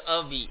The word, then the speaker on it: Abhi: Let's say my name is Avi, A-B-H-I.